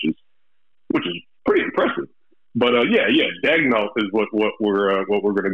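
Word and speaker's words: Daigneault: Is it Dagnault or Dagnault? Like, what is the correct pronunciation? – I'm going to go with Dagnault. Okay. That's what it is.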